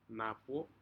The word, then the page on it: napo: Aneityum